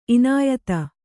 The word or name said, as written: ಇನಾಯತ